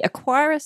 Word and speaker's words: Aqara: Yeah, I'm going to pronounce this Aqara, even though it's not got a U in it.